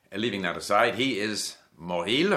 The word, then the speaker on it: Mauril: M-A-U-R-I-L, Moril. By the way, words in French that end in I-L are, half the time the I-L is pronounced as il, and half the time it's not pronounced like le nombril.